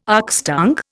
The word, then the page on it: oxtongue: Indian